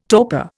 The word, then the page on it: torpor: Indian